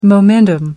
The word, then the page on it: momentum: American English